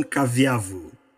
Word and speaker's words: Kavyavu: Did I pronounce that correct, John? – Almost. – Ah. John Kavyavu. – Kavyavu. Correct? Yes, yes okay.